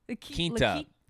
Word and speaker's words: Quinta: It's spelled the same as La Quinta on the side of the road. It's Quinta? – Quinta.